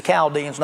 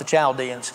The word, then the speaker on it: Chaldeans: Just a side note, when you're reading God's word and you come to any word that has a CH in it, it's almost always pronounced with a K and not a CH. It's the Chaldeans, not the Chaldeans.